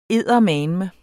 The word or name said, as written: eddermame